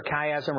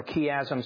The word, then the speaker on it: chiasm: And interestingly, I think there is a chiasm here. And you know, remember a chiasm or chiasm, some people pronounce it chiasm,